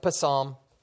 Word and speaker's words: Psalm: Might throw some of you because it looks like Psalm, but it's actually pronounced Psalm.